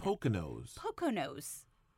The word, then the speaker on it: Poconos: How do you say that? – Poconos.